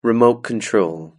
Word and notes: remote control: British English

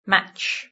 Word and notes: matches: British English

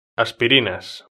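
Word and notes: aspirinas: Spain

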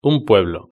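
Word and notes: un pueblo: Spanish (Spain)

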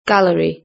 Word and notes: gallery: British English

